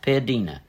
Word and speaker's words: piadina: How would you pronounce that? Piadina? – Piadina.